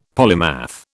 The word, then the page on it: polymath: British English